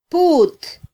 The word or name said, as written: put